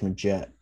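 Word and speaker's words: Magette: That point guard, what was his name? McGetty? – Josh Maget.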